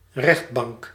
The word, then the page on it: cour: France; [kuʁ]